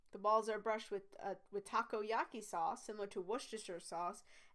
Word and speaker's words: takoyaki: According to Wikipedia page, it's takoyaki. So I mispronounced that.